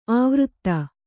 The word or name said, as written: ಆವೃತ್ತ